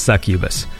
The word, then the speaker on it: succubus: She's a succubus? Succubus? How do you pronounce it? Yeah, that's the word. Succubus is a word I never say. But yes, it is succubus.